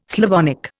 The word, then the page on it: Slavonic: Indian